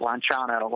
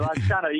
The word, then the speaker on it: Lanciano: So the first one was in the 8th century in Lanciano, Italy, or Italians pronounce it Lanciano when I, my Lanciano. – Wife and I were able to exactly, Lanciano. You got to have your fingers in the air when you say Lanciano.